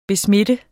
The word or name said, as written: besmitte